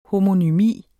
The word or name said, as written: homonymi